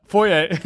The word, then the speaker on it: Foye: Foye Oluokun. I don't know why you spell it Foyisade, but. – We just don't say the Sade. – We don't, yeah. So, Oluokun. – Yeah, yeah. Foye.